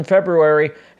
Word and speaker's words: February: Also, I pronounce both R's in February, as we've been over in the past.